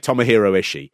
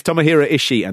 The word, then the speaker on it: Tomohiro Ishii: I don't say Tomohiro Ishii. – Yeah. – I don't go, Tomohiro! like yeah. that. But that is the slight sort of Japanese way of pronouncing it.